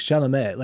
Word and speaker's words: Chalamet: His pronunciation of Chalamet was actually really good. Like Chalamet, like it was like, he got it right.